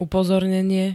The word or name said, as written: upozornenie